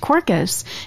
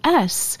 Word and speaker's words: Quercus: And it's actually pronounced Quercus. It's Quercus.